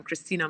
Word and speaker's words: Kristina: If you want me personally, it's Instagram, Kristina Mand, and my name is spelled very oddly, Kristina with a K.